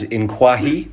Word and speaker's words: INQAAHE: Inquahi, and I'm sure that's wrong, but that's however I always have it sounding in my head. – That's pronounced correctly.